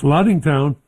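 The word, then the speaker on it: Lattingtown: L-A-T-T-I-N-G town. – That's Laddingtown.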